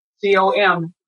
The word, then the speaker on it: Comeaux: And the Comeaux is very French, Comeaux.